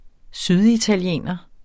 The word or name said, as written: syditaliener